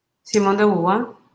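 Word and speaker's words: Simone de Beauvoir: Simón de Beauvoir.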